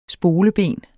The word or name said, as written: spoleben